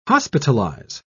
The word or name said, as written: hospitalize